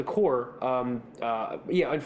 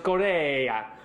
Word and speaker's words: Kore: So, Michael, you brought up the core. And correct us if we're saying that wrong. Core, K-O-R-E. What he's it is pronounced Coer. – It's guys. It's pronounced Corea.